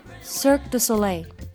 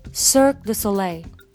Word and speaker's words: Cirque du Soleil: Cirque du Soleil。 Cirque du Soleil。